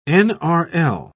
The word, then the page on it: nrl: British English